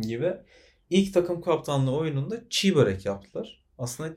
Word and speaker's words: çiğ börek: Sokak ağzı öyle ama okunuşu ve yazılışı normalde çiğ börek olması gereken bir ürün.